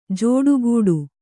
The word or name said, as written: ಜೋಡುಗೂಡು